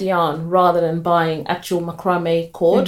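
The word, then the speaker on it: macramé: When I watched one of her videos, she did a macrame. Am I saying it right? Macrame, right? – Yeah. – Yeah. – As far as I know.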